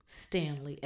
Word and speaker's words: Stanley: All of my social media handles are my name, which is spelled with an I, J-O-I-M, like Mary, Stanley, S-T-A-N-L-E-Y.